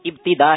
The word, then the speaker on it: ابتدا: ابتدا یا ابتدا صحیح تلفظ کیا ہے؟ – صحیح تلفظ ابتدا ہے, شروع کرنا. – اچھا ابتدا کا مطلب ہے آغاز کرنا, شروع کرنا, اشاروں کی زبان میں شروع کرنا. ابتدا یہ لفظ غلط ہے, یہ اعراب ٹھیک نہیں ہے. ہم جو شروع کرنے کے لیے بات کرتے ہیں یہ ہے ابتدا.